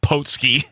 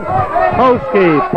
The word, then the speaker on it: Putski: And I like the way Marty pronounces it, Potski. – Yes, just blatantly. Doesn't even correct him. – Ladies and gentlemen, Ivan Putski.